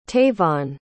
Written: tayvon

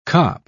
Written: cop

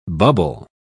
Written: bubble

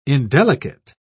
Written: indelicate

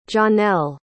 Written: johnelle

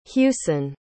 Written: hewson

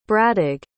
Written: bradig

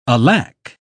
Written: alack

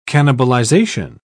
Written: cannibalization

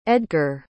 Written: edgar

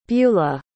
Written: bula

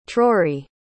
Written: trory

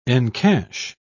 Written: encash

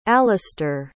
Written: alastair

